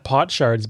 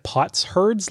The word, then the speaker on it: potsherds: I'm glad you pronounced that pot shards, because when I was looking at it, I thought it was like pots herds, like shepherds or something.